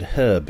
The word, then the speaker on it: herb: You call it herb, we call it herb because there's a fucking H in it.